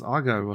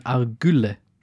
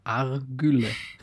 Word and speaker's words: Argylle: Du meinst Argyle wahrscheinlich. – Ja, ich habe jetzt auch gelernt, ne, also nachdem man den Film gesehen hat, spricht man das ja anders aus. Das heißt dann Argylle. Argylle.